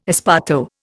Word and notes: esparto: Indian

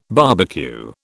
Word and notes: barbecue: British English

